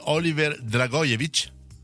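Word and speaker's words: Oliver Dragojević: è quella di Oliver Dragojevic, detto bene? – Sì, giusto.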